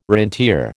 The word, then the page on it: rentier: American English